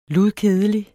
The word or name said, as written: ludkedelig